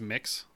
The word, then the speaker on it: Mx: Or if you, like, if there's an other option, it would be, I think it's pronounced mix, MX or whatever the fuck it is, or they, them.